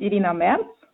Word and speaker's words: Irina Merz: Mein Name ist Tamara Krutschau und ihr sagt bitte eure Namen selber, damit ich nicht irgendetwas falsch ausspreche. Hallo, mein Name ist Irina Merz.